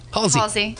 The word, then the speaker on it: Halsey: Halsey.